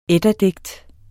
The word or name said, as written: eddadigtene